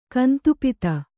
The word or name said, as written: ಕಂತುಪಿತ